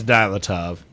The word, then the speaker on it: Dyatlov: Wait, why do you have to say it like that? – Because that is Russian pronunciation. – It's Dyatlov. – Fuck you, that's what I said originally. – We're trying to French it up, dirt. – You mean Russian it up? – Whatever.